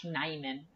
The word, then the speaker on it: Nijman: it's Yosh Nijman. I believe the Nijman. – J is silent. – There's a J in there that apparently is silent.